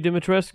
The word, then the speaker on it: Dimitrescu: Demostreia? – No, Lady Dimitrescu.